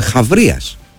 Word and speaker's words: Χαβρίας: Τσάμπριας. Χάμπριας. Δεν ξέρω πώς ακριβώς διαβάζεται αυτό. Και τι είναι ακριβώς. Είναι, συγγνώμη, Χαβρίας. Αθηναίος στρατηγός του 4ου αιώνα. – Χαβρίας. Πολύ Χαβρίας, ωραία. Λοιπόν.